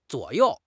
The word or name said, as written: zuo3 you4